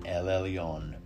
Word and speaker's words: El Elyon: El Elyon. It designates him as sovereign over the universe. If you want to spell it correctly, it is E-L space E-L-Y-O-N, El Elyon.